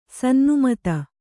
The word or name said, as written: ಸನ್ನುಮತ